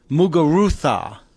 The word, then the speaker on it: Muguruza: And for the record, Garabina Muguruza. Yes, I prefer the Castilian pronunciation on Spanish.